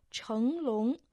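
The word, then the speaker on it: чэн лун (成龙): Да, китайское имя Джеки Чана состоит из двух иероглифов «чэн лун». Первый иероглиф «чэн» произносится во втором доне, имеет значение «стать», а второй «лун» произносится дальше во втором доне, означает «дракон».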